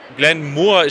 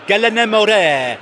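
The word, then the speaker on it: Glen Moray: Glenn Moore, ich dachte Glenn Moray heißt das. Aus der Regie kommt jetzt Glenn Moore. Äh, nein, Nico, du bist ja kein Whisky-Trinker, oder doch? – Ich glaube, die richtige Aussprache ist Glenn Moray. das bezweifle ich jetzt ganz stark. Nein, ich glaube, ich glaube es ist Glenn Moray.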